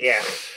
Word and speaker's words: Aech: Aech pronounced his own avatar's names just like the letter H. No, he did not. He pronounces it like Ech.